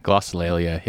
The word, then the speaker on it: Glossolalia: Hold on. Glossolalia? – Glossolalia, yeah. – Oh, I pronounced it right? – Yeah. – It is glossolalia.